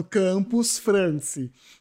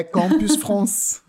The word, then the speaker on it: Campus France: Olha que pronúncia. Nossa, os franceses que não me ouçam falando Campus France, que é Campus France.